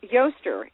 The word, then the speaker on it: Eostre: Goddess for March 22nd is Yostre. It's spelled E-O-S-T-R-E, and by the way, it's the origin of the word Easter.